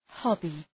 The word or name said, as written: hobby